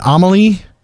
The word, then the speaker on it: Amalie: Is it Amalie? – Yes, yeah, Amalie Bruun from Myrkur.